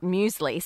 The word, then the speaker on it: muesli: We call it muesli.